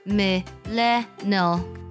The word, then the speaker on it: millennial: 千禧一代这个单词读作 millennial。 Millennial，它拼作 MILLENNIAL。